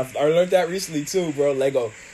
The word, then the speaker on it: Lego: It's not Legos. It like, cause I was watching Fact Fiend, and it's like, it's not Legos. It's Lego. I'm like, what? – So yeah, it is Lego.